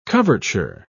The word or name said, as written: coverture